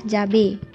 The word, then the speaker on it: jabay: Perkataan seterusnya ialah jabe. Ejaannya J-A-B-A-Y.